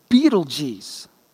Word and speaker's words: Betelgeuse: The star Betelgeuse, I said that right, however, has a diameter of 100 million miles, which is larger than the Earth's orbit around the sun.